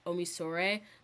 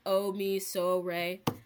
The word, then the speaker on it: Omisore: You don't pronounce the N, and then my last name is Omisore. So if you think of like treble clef in music class, like Omisore, that's how you pronounce it.